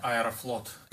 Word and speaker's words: Aeroflot: Aeroflot.